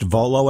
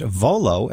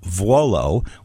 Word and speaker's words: Vuolo: Hey, Art Vuolo, I just found out your name is pronounced Volo and not Vuolo. I've known you for years. It's V-U-O-L-O. How come you never told me until now? – Well, I just figured you knew that. You know everything, Michael. I mean, you're a learned man. You're one of the best in the business, so I just figured you had heard it enough times. – Uh-huh. – The U is silent.